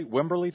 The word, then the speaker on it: Wimberley: Is that how you say it, Billy? Wimberley, Texas? – That's correct, Brad.